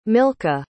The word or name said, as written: milcah